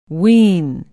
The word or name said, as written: wien